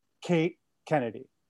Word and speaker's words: Kate Kennedy: Uh, hilarious stand up comedian and former porn star, Kate Kennedy.